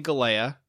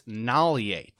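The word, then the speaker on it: Galeai: His last name, Galei. I've been saying Galea. He pronounces it, the G as an N, so it's Nalie, Tipa Nalie.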